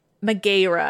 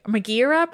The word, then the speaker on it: Megaera: These three women, their names were Alecto, Megara, Megaera, I should say. Megaera probably is the pronunciation, and Tisiphone. It's spelled differently, as far as I know, than the Heracles Megara. So I'm going to say Megaera.